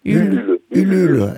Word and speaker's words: Ulule: participation ou c'est que non de participer dans le Ulule, comment ça se prononce Ulule? – Ulule, elle parle la française.